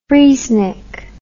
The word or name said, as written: freezenik